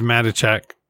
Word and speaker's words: Matichak: McKittick? Did they say it? – I think it's Matichak.